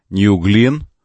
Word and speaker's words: New Glenn: Согласно трансляциям сайтов ESPN, мегаракета New Glenn, правильно говорить, ну, в честь астронавта.